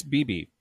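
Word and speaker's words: bibi: It's BB. And the reason I know it's BB is because this book, Fantabulosa Dictionary, also gives pronunciations in IPA format. And so it's BB.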